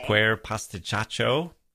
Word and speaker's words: Quer Pasticciaccio: Okay, so let's take a look at the third book, which is Quer Pasticciaccio, if I'm pronouncing that correctly, Brutto on Via Merulana by Carlo Emilio Gada.